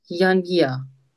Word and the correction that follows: Januvia: Mit U. – Okay. Also können Sie noch mal aussprechen? – Okay, Januvia. Ja. – Januvia. – Ja, länger.